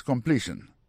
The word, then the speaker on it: completion: Sentence completion. Pour completion nan li même, accent tombé sous deuxième syllabe là.